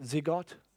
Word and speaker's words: zygote: That cell, I think, I don't know how you pronounce it, but somebody will correct me, no doubt, is spelt Z-Y-G-O-T. Is it zygote? Zygote? Thank you.